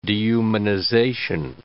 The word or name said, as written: dehumanization